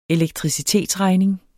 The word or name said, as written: elektricitetsregning